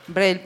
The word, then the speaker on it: Brel: Y en Europa fue Jacques Brel, bueno, con la pronunciación francesa. Brel,